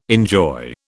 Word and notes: enjoy: British English

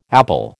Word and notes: apple: American English

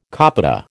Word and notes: copita: American English

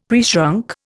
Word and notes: preshrunk: Indian

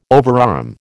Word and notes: overarm: American English

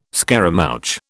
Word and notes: scaramouch: British English

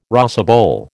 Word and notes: rosebowl: American English

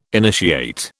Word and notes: initiate: British English

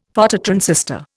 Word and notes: Phototransistor: Indian

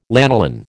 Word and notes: lanolin: American English

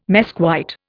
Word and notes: mesquite: Indian

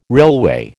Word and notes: railway: American English

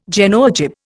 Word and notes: Genoa jib: Indian

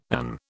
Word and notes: and: British English

